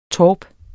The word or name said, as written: torp